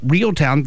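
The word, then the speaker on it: Reeltown: But Real Town, that's in the college or the high school football playoffs, is spelled R-E-E-L-T, mm-hmm. like real, like a fishing reel.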